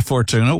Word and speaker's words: Fortuna: Yeah, Fortuna.